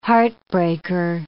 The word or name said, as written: heartbreaker